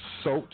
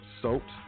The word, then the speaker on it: Sot: Pronounced SOT, like nope, but SOT, L, S-O-T.